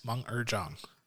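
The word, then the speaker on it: Meng'er Zhang: Meng Er Zhang. – Yes. Meng Er Zhang.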